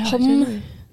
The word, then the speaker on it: Homme: Yeah, Homme.